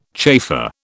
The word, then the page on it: chafer: British English